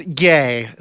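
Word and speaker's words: Ye: It's Yay. So just like that last syllable of his name. – Yay. Yay. It's not Yee, it's Yay.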